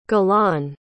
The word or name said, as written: galan